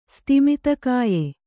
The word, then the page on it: ಸ್ತಿಮಿತಕಾಯ: stimita kāya